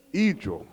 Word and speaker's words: hijo: No, there was one word she said, son, Hijo. See, I wrote it, but I didn't spell it right, H-I-J-O, and the H is silent.